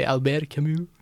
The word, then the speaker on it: Albert Camus: No, but it's definitely Albert Camus.